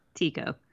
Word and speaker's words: Tycho: So, is it Tycho or is it Tycho? – I know. When I was saying it, I was like, I'm probably saying it wrong, but I feel like in Star Wars, you're allowed to have like a couple different pronunciations for the same word. So how do you say it? – Tycho. – Tycho. Okay.